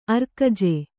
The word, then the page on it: ಅರಗಜ: aragaja; kannada